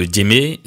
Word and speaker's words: djembé: Et après, nous, on va leur offrir une prestation avec le djembé et le groupe Nzasa Faré. – Le djembé? – Ouais. – Ouais, tu prononces mieux que moi, mais d'accord, le djembé, oui, la percussion, les percussions.